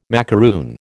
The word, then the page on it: macaroon: American English